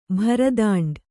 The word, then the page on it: ಭರದಂಡು: Kannada